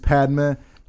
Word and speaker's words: Padma: Just say Padma.